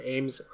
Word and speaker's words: Ames: Ames rhymes with Ames or James. There you go. – Oh. – The S has a Z sound, not an S sound.